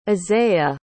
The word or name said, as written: Asaiah